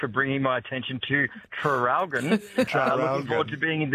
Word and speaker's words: Traralgon: Seeing as you asked about the spelling of Traralgon, could you please pronounce the first R? – Oh, Traralgon.